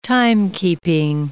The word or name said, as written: timekeeping